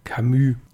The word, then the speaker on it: Camus: Ich habe am Sonntag den Autor falsch ausgesprochen. Er heißt Camus und nicht, wie ich ihn ausgesprochen habe.